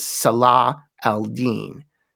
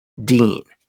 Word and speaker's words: Salah al-Din: The name is Salah al-Din, meaning righteousness of the faith, the last syllable being the important one, deen.